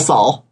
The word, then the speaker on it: Sael: Well, pronounced that way, but that's different. female Saul. – Okay. – S-A-E-L.